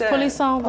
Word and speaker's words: Poulisson: No, because a lot of people are like, they say Poulisanti, and they like add all these syllables. And I'm like, no, like we don't have syllables like that. In my culture, it's just not there. – You just do it straightforward. – Yeah, it's just polisson.